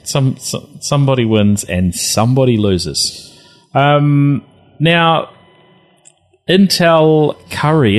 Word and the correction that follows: Curie: is that how you pronounce it, uh, Bill? Oh, to a Curry after Curie? Madame Curie.